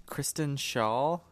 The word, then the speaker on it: Kristen Schaal: Kristen Schaal, I think.